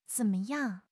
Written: zen3 me5 yang4

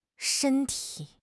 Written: shen1 ti3

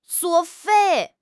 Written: 作废

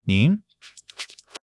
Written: 您

